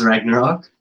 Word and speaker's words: Ragnarok: Checked out Thor Ragonk or Ragnarok, however your local... – For English listeners, Ragnarok.